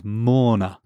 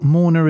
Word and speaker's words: Morna: And is this spelt Morna as in like somebody attending a funeral? – It sounds like it, but no, it's M-O-R-N-A.